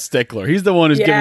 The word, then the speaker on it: Stichler: It's spelled S-T-I-C-H-L-E-R, so it might not be Stickler, but like I think it's Stickler. No, it definitely is. And that's even more principal. Like it'd be like, look, it's D-Y-C-K-B-U-T-T-E.